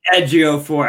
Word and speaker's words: Ejiofor: It is Ejiofor. I swear I read this before. I look at the pronunciation of his name. I got Chiwetel right. You can go on. – Chiwetel Ejiofor. – Yeah, Ejiofor. I think I just mispronounced the phonetics.